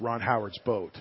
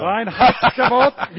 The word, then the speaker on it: Reinheitsgebot: My chef likes to refer to it as Ron Howard's boat. – Ron Howard's boat. – It's a big deal. – Reinheitsgebot. You will not pronounce it incorrectly.